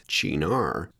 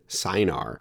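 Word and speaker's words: Cynar: But before we do, note Chinar is pronounced Chinar. I always pronounced it Sinar. I pronounced the CY as a S sound as Sinar, but indeed it's more like a hard C in Italian, so it's Chinar.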